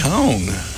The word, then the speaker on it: Hong: So an actress named Hong, H-O-N-G, is that pronounced Hung or Hong? – I think Hong. – Hong Chow, not to be confused with Hung Chow.